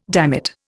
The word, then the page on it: dammit: Indian